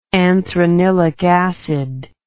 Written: anthranilic acid

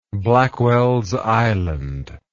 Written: blackwells island

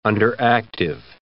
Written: underactive

